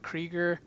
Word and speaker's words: Krygier: You could reach Rob at Rob Krieger. Sounds like Seeger, but it's not spelled like that.